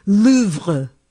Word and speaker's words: Louvre: I'm not saying our French teachers were the best, but clearly I was learning it incorrectly, because Babbel is reminding everyone that the correct pronunciation for the famed museum is Louvre, with a very soft pronunciation of the R at the end. So it's Louvre, something like that.